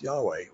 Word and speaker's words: Yahweh: And it's pronounced Yahweh, YHWEH.